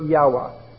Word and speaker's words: Yahweh: Hebrew scholars prefer Yahweh, and that's the way you find it in Rotherham's translation.